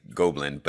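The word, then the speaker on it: Gobelins: And I'm gonna probably hack on how you say it, because I would say Gobelin, but... – Gobelin, yeah.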